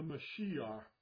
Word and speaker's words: Mashiach: He arrived at the Feast of Shavuot to pour himself upon the followers of Yeshua Jesus, the Mashiach, which is the Hebrew pronunciation of Messiah.